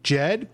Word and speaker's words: Ged: Jed, but it's spelled G-E-D.